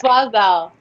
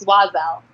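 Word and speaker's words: Oiselle: You have a new collection out with, and I do not know how to pronounce Oizel. – It's Wazel, and that's okay. I never knew how to pronounce it either, but it's Wazel.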